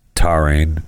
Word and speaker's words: taurine: So we know that low levels of taurine. Is that how you say it? – Taurine. – Taurine. All right, that's pretty close.